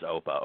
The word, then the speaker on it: Oppa: I think pronounced OPA, O-P-P-A.